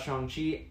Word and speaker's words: Shang-Chi: But yeah, we'll talk about Shang-Chi and whatever Doctor Strange is about to show up tomorrow.